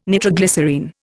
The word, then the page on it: nitroglycerine: Indian